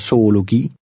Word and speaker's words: zoologi: When a Z is used, it sounds like an S. Read number 7, which has a hard G in the last word. – Min søn studerer zoologi.